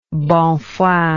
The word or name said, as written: bonne foi